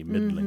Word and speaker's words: middling: Middling, by the way, just for everyone, is we're not pronouncing the G at the end, but it looks like middling, M-I-D-D-L-I-N. Middling.